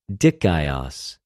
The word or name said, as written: δικαιος